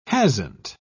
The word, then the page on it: hasn't: British English